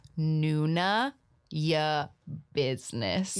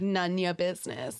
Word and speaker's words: Nuna Ya Business: And just because I think the last time I said the name of their show on the podcast, I didn't pronounce it very well, so it's Nuna Ya Business. – Yeah, like Nunya Business. – But Nuna, like Nuna.